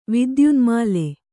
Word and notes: ವಿದ್ಯುನ್ಮಾಲೆ: vidyunmāle

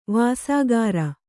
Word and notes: ವಾಸಾಗಾರ: vāsāgāra

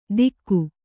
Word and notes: ತಿಕ್ಕು: tikku

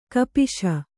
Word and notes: ಕಪಿಶ: kapiśa

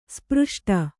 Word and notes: ಸ್ಪೃಷ್ಟ: spřṣṭa

